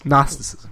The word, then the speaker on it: Gnosticism: I think there are two ways we can categorize Gnosticism. There's like the modern day... – Are you sure you're pronouncing it right? There sounds no way that that's how it's... – Gnosticism, yes.